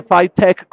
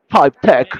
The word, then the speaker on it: FiTech: También me supongo que tienen página de Instagram, lo buscamos como FiTech Pitesh, que se pronuncia Five Tech con este acento británico que poseemos. Five Tech.